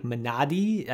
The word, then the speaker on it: Minari: I know that the proper pronunciation of Minari is, I think, Minadi, I think is closer to the way that you would pronounce it, but my American tongue is just not going to make that work.